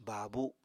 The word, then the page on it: baabu: apatani